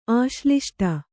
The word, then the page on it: ಆಶ್ಲಿಷ್ಟ: āśliṣṭa